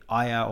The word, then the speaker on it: Aijā: You've got the kind of um, the ayah or um, that is how you pronounce it. – Ayah. Yes. Yep.